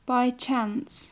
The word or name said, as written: by chance